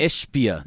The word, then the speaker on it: Ishbia: And one more time, his pronunciation of his name, the flopping owner, is Ishbia? – Ishbia. No extra I.